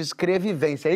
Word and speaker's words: escrevivência: Ela chama isso de escrevivência.